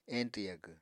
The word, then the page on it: eñtw yagw: Apatani